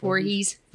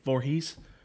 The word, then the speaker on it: Voorhees: Miss Voorhees? How do you say it? – Voorhees. – Voorhees.